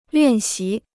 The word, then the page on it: 练习: liàn xí